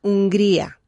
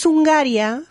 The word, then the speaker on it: Hungría: as in the country, in English is spelt H-U-N-G-A-R-Y. Yeah. Okay. Yeah. But in Spanish, it's Hungría. – Yeah. – Okay, there's no A there, apart from the one at the end.